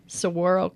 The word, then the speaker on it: saguaro: So you're one of the first, this will be interesting for the listeners, the first commercial scale saguaro cactus nursery growers. Am I saying that right? – Well, yeah, I wouldn't say one of the first,